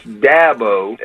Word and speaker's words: Dabo: It's Dabo, as in Apple.